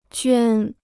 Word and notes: 捐: juān

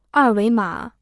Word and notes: 二维码: èr wéi mǎ

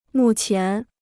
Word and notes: 目前: mù qián

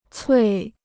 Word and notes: 脆: cuì

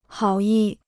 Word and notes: 好意: hǎo yì

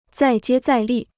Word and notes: 再接再厉: zài jiē zài lì

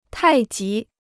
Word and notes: 太极: tài jí

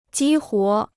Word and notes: 激活: jī huó

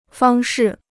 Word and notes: 方式: fāng shì

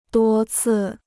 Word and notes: 多次: duō cì

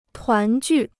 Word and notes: 团聚: tuán jù

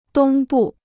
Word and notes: 东部: dōng bù

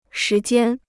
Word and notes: 时间: shí jiān

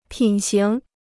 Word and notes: 品行: pǐn xíng

